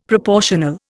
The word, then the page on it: proportional: Indian